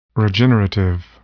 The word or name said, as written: regenerative